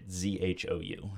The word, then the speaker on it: Zhou: Are you pronouncing it correctly? – I'm pretty sure that's right. Z H O U, Zhou. Um, that's how I'm going with it anyway.